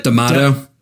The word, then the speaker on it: D'Amato: D'Amato, how do you say that? – D'Amato.